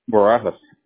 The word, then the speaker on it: Barajas: Barajas. – Braz. How do you spell that? – B-A-R-A-J-A-S. – A-J-S. Okay, so B-A-R-A-J-A-S.